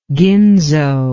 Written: ginzo

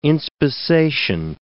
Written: inspissation

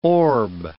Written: orb

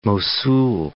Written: Mosul

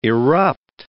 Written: irrupt

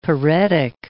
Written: paretic

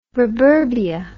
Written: ruburbia

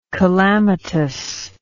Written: calamitousness

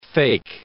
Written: fake

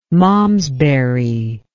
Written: malmesbury

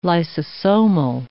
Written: lysosomal

